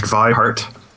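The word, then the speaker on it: Vi Hart: The next pick that I have is a set of math videos from, I think it's pronounced Vihart.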